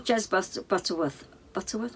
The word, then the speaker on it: Jez Butterworth: But no, what it did, this film, was when I saw Jez Butterworth, am I saying that right? – Yeah, yeah.